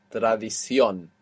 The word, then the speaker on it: tradición: That last O will have a written accent on the end, showing you that you're stressing it. Tradición.